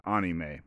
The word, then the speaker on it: anime: And in Japan, you would pronounce it anime. Yeah, anime. But I'm just going to call it anime because I'm an American.